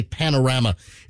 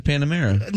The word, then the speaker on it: Panamera: Is that how you say it? I always want to say Panorama. The Porsche Panorama. Panamera. Anyway, wait. Yeah, it's a Panamera.